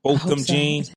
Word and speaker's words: Botham Jean: Ugly Crying Lady, the one-time police officer who fatally shot Boheme Jean. Is that how you say his name? Botham Jean.